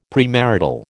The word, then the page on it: premarital: American English